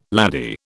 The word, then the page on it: laddie: British English